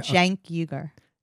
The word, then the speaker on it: Cenk Uygur: I'm getting the correct pronunciation of that guy's name. – Cenk Uygur. Okay. – Cenk Uygur.